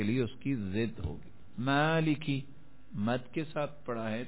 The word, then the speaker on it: مالکی: اب لفظ ہے مالکی, تلفظ ایسے کیا ہے مالکی, میم کے بعد مد کر دی,